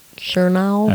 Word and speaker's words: Chernow: I think his last name's pronounced Chernow. – I think that's right.